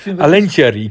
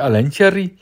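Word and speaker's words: Alencherry: A-L-E-N Sherry. Met CH. Ja, Alen Cherry. – Ik heb het dus opgezocht op YouTube hoe, hoe Indiërs dat uitspreken. Alen Cherry.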